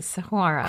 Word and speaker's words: Saguaro: And another park that is threatened is Saguaro. Did I say that right? – You did, Saguaro.